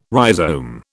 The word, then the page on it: rhizome: British English